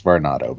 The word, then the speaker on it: Varnado: Varnado. – I just realized it's spelled like tornado, but with a var. – It's Varnado, but that's okay.